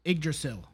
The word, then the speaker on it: Yggdrasil: I do not know how to pronounce that. – Yggdrasil. – There you go. You know how to pronounce it.